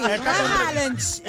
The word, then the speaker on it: Haaland: É, gente, é a pronúncia do nome dele. Haaland.